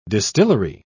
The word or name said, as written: distillery